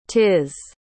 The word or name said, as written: 'tis